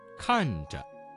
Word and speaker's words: 看着: 看着，看着。 – 这个词当中的遮字读成了轻声。